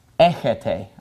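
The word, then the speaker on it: echete (ἔχετε): This word is pronounced echis, and this is pronounced echete. All right, so echete and echis are the second person, you, singular and plural.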